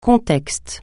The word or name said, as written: contexte